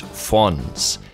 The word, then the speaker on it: Fuenz: Now, the name of the artist is Fonz. I'm going to spell that for you. F-U-E-N-Z.